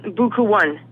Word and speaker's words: Bukue One: So we're going to hear Sparks featuring, and how do you say the MC's name? – Bukue One. – All right, featuring Bukue One.